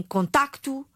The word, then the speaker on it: contacto: Se eu digo o C em facto, em contacto, em, por exemplo, mais o P em rapto, digo o P em rapto, digo o C em técnico, se eu pronuncio, eu escrevo. Então, técnico tem C, escreve-se com C, contacto escreve-se com C, contactar com C, facto com C, rápido com P. Se eu pronuncio estas duas consoantes, escrevo. Se eu não pronuncio, não escrevo.